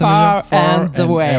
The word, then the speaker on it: Far and Away: Ça, c'est Far and Away.